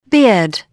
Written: bead

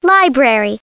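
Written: library

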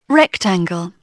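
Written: rectangle

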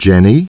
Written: Jenny

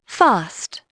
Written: fast

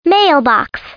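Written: mailbox

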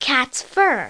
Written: cats fur

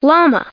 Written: llama

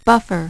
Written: buffer

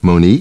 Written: Monie

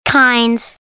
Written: kinds

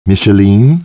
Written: Micheline

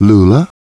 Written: Lula

